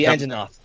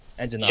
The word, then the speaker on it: Anjanath: how do you Angirath? Is that how you pronounce it? The big T Rex. Uh, the Anjanath. – Anjanath. – Yeah, Anjanath.